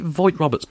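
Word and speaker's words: Vogt-Roberts: Is it Voight Roberts, by the way? – That's what I've been going with. Because it's spelled V-O-G-T.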